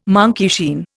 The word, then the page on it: monkeyshine: Indian